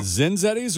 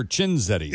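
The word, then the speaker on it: Cinzetti's: Is it Zinzetti's or Chinzetti's? – It's Chinzetti. It's spelled with a C-Z-I-N. – C-I-N-Z-E-T-T-I.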